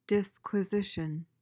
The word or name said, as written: disquisition